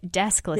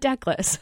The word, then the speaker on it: Deskless: Deskless. – Yep. Got it. – You say that a lot. I'm learning to say that. Deskless, not deckless.